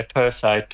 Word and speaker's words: Perseids: If I'm to pronounce it correctly, yeah, Perseide. P-E-R. Have you got it? P-E-R-S-E-I-D-S.